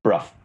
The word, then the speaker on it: Bruff: Now, DCI Bro, is it Bro or Bruff? How do I say it? – Bruff.